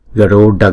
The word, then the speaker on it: Garuda: Asura Garne, Garuda Garne, Garuda Garne. Chữ D này có dấu nặng, chúng ta đọc là chúng ta đọc D, cong lưỡi lại và búng ra D.